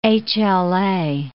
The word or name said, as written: HLA